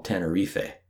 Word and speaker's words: Tenerife: Just a note, we're using the English pronunciation of the island. In Spanish, it's called Tenerife. The island is about 2,000 square kilometers in size, and its population is approximately 900,000.